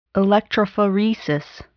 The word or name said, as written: electrophoresis